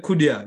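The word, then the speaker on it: Cunha: After Watkins, I would say Kunha. I don't know if I pronounced his name. – Kunha. – Is that his name? Kunha. Yeah, Kunha. Yeah, Kunha.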